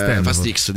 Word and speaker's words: Fast X: Fast X dicono tutti, Fast X, però okay. Dovrebbe essere Fast 10, infatti.